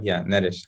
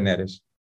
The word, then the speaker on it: Neres: André was perfect. Neres is more like, Neres, André Neres.